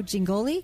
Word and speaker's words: Jingoli: Jack Morris and Joe Jingoli? – Yes. – Did I say it right? I didn't check. – Got it right.